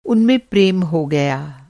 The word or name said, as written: प्रेम